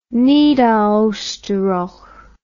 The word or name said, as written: Niederosterreich